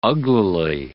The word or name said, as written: uglily